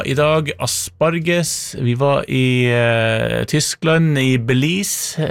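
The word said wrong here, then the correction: Beelitz: Belitz, kanskje riktigere uttale, Belitz in Deutschland.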